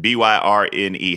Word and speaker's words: Byrne: B Y R N E. How do you pronounce that? Byrne grants.